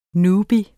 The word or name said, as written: newbie